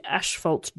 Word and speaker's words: asphalt: I just didn't want to hear, you thought asphalt funny. We're in a different country. – Is that so Americans say asphalt? – Asphalt. We do put an S in there, a H in there that doesn't really.